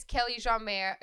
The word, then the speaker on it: Kelly Jeanmaire: My name is Kelly Jeanmaire. Again, if you don't know how to say it, just think of John Mayer. – Mm-hmm. Um, that'll be the closest way to get there.